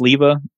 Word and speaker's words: Sliva: P-L-U-M. – Like there's no B. – Yep. P. – Okay. – Yeah, my last name is pronounced Sliva. It means plum in Spanish and Czech.